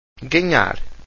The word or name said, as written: ganhar